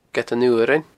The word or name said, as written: Gead an Fhuarain